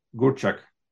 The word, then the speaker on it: Gurczak: Today, it is my pleasure and a privilege to welcome Andy Gurczak. Am I pronouncing correctly, Andy? – You are. You actually said it perfectly.